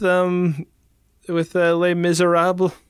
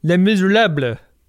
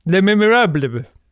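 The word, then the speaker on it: Les Misérables: Well, I know your pronunciation is dead wrong. It's supposed to be called Les Miserables. Try it. Les Miserables.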